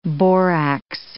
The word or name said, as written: borax